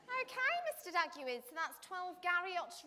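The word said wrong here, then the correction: Garioch: No, it's 12 Geary Road, Fingen!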